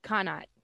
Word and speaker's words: Connacht: Am I saying that right? – Connacht. C-O-N-N-A-C-H-T? – Yes. Yes, ma'am.